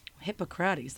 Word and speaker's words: Hippocrates: Hippocrates pronunciation, like Hippocratic oath, Hippo. Hippocrates. I knew I was going to do that. Hold on. Here we go. Hippocrates. That doesn't make sense, Spain.